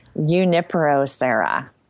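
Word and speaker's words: Junípero Serra: Juniper Sierra, who's from your home state of California. – Let's have a pronunciation lesson. – All right. – Allison, you cannot mispronounce his name. Junipero Serra.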